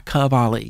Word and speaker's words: Kovaly: Oh, if you want to hear him as a sideman, go and check out the Mark Cavalli single, K-O-V-A-L-Y, Cavalli, yes, I didn't spell that phonetically.